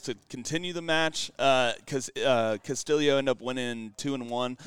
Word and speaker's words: Castillo: I also wanted to bring up John Murphy in his match versus Ricky Castillo is how we learned how to say it, because he actually has Filipino background in his family. So even though it looks like Castillo or Castillo, it's Castillo.